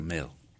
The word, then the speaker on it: Mhaol: Mail se pronuncia. Eh, está escrito, no sé, en algún vocablo, eh, M-H-A-O-L, pero se pronuncia Mail.